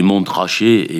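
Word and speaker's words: Montrachet: Écrivez Mont Rachet et prononcez Mont Rachet comme le Mont Chauve, le Mont Pelé.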